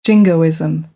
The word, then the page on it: jingoism: American English; oʊ